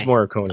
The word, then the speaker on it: Morricone: It's Morricone, correct? Or is it Morricone? – Oh, how do you pronounce it? – Yeah. – No, but... – It's Morricone. – It is Morricone.